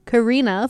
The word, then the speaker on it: Kari: Founded by international couple Karina from Germany and Tyler from Kutztown, the Just Imagines started a duo when Tyler and Carrie pronounced like the word car with an E.